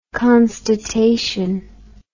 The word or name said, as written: constatation